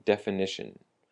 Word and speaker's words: definition: Definition, the emphasis on the ni, the n sound, definition.